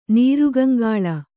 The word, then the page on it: ನೀರುಗಂಗಾಳ: Kannada